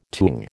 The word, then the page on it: tung: American English